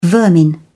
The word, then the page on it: vermin: British English